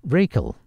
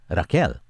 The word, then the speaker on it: Raquel: In North America, her name is pronounced Rachel, but in Latin America, it's pronounced as Raquel.